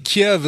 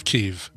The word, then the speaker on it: Kyiv: So correction, Kiev is the Russian pronunciation of Kyiv.